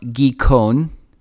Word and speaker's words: Gihon: So Genesis 2:13, and the name of the second river is, and it looks like in English, it should be Gihon or Gihon at least, but in Hebrew, it's pronounced Gikon.